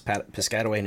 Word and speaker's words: Piscataway: It's Piscataway, New Jersey.